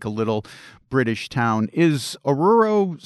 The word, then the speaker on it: Oruro: And one of the places that really interests me was a place called Oruro. Is that the correct pronunciation? – Oruro, yes. Yes, it is.